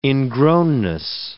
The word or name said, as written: ingrownness